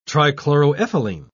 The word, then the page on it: trichloroethylene: British English